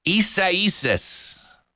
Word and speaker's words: Isaias: And then the weekend, we start talking about the potential for a wet weekend with the remnants of Isaias. That's how you say it,